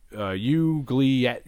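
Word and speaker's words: oubliette: She goes into this world where she's underground, and she gets stuck in this thing called the Ugolet. I'm pronouncing it so wrong. It's a French word, a Ugliette, and it's...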